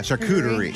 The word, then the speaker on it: charcuterie: Is that how you say it? Charcuterie? – Yeah, charcuterie.